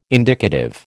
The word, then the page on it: indicative: American English